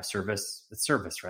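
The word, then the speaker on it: Servais: Everything that I've read, DePoto or Cervais has said service, right? That's how you say it.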